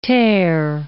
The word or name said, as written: tare